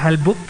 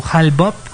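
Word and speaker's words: Hale-Bopp: Bien, el otro, otro de los cometas importantes es el Halbup o bueno, sí, se pronuncia Halbup eh, o Halbop.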